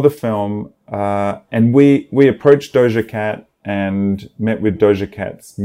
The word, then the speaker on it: Doja Cat: Is that how you say it? Doja Cat? – Doja Cat. – Oh, okay. Doja Cat. Doja. Oh, what is it? Doja or Doja? – Doja. – Doja Cat.